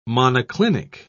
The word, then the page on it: monoclinic: British English